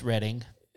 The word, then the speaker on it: Reading: Well, it's Reading. – Yeah, I know that they think that. I refuse to enunciate that word when it's spelled Reading. – So, it's not the only Reading in the area. Because the most famous one is Reading, Pennsylvania. – Right, and they're wrong too.